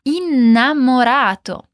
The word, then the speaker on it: innamorato: Para dizer sou apaixonado, vamos dizer sono innamorato. Presta atenção aqui na pronúncia. Innamorato tem dois N's. E por isso vamos dizer um som demorado, um som alongado. Então, podemos dizer primeiro in e depois namorato. Innamorato.